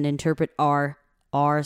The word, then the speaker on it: interpret our: Okay, 我就把这个字一个一个音的拆开来念，让大家可以清楚的听到这个发音是怎么发的。好，interpret 后面这个字 our 就不会念成 our 了，会念成有点像 a-r-e-r, r, 就是会变成 human faces and interpret our social cues.